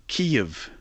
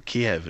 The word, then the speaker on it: Kyiv: Take the world, and we go to the Ukraine, to Kyiv, or Kiev.